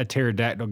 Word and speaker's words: pterodactyl: Hey, why can't you hear a pterodactyl go to the bathroom? – I don't know. – Because the P is silent.